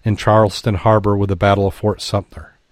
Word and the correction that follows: Sumter: Sumter, Sumter. I added an extra syllable in there, I think.